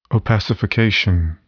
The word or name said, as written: opacification